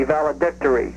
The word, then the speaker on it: valedictory: I think the accented syllable is D-I-C, valedictory.